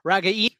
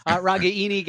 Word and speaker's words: Ragaini: It was a tight ends game, only 3 tickets overall for the wide receiver position, 1 each to Ragaini. Yeah, I got that right. Got the Italian pronunciation there. Ragaini got 1 to Arlen Bruce, and also 1 to Brody Breck.